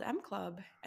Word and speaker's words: Emme: And my new baby is called M Club, and it's spelled like E-M-M-E Club.